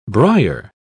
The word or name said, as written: brier